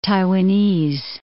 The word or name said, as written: Taiwanese